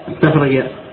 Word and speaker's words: استخرج: الواصل نحو استخرج استخرج، هنا هذه الهمزة تسمى همزة واصل وهي على شكل ألف، ما ننطق بالهمزة استخرج، ننطق بها نعم في الأكتب لا تكتب الهمزة، وهي لا تنطق إلا في إيش الابتداء، أما إذا كانت في الجذب في الوسط قبلها كلام فإنها تسقط، وهذا الأصل فيها، ولذا سميت همزة واصل.